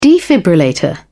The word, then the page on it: defibrillator: British English